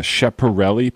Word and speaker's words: Schiaparelli: It took a while to get this, and you'll have to help me on the pronunciation, the Schiaparelli probe. Am I saying that right? – Yeah, darn good.